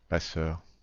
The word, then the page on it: passeur: IPA: /pa.sœʁ/